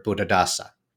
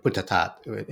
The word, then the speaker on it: Buddhadasa: Buddhadasa. So, or Buddhatat in, I guess would be the more proper Thai pronunciation.